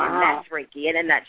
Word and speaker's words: Reiki: How do you pronounce that one? – That is pronounced Reiki.